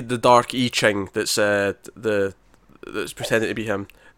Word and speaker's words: I Ching: Remember, I Ching, and Matt. That's how you pronounce it. – I Ching, that's right.